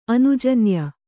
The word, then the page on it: ಅನುಜನ್ಯ: anujanya